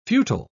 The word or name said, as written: futile